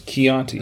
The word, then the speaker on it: Chianti: I thought it said Chianti. – No, it's Chianti.